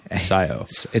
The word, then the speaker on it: Scio: Right. S-C-I-O. SIO. – It pronounced SIO, too.